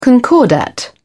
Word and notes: concordat: British English

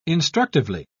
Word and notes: instructively: British English

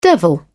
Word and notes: devil: British English

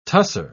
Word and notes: tusser: British English

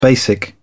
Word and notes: basic: British English

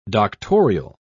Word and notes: doctorial: British English

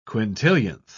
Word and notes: quintillionth: British English

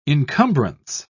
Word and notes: incumbrance: British English

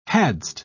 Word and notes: hadst: British English

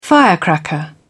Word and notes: firecracker: British English

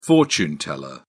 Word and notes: fortune teller: British English

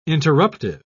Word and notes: interruptive: British English

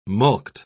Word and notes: mulct: British English

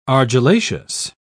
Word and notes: argillaceous: British English

